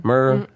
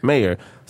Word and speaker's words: Maher: Well, how you pronounce it? I can never pronounce his last name properly. Bill Maher? – Maher. – Maher.